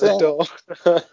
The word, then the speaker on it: Krasnodar: Is that how you pronounce Krasnodar.